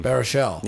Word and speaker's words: Baruchel: Also, Jay Baruchel. – I never know how to pronounce his name. – Baruchel.